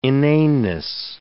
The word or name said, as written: inaneness